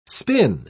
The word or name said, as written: spin